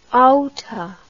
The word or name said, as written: outa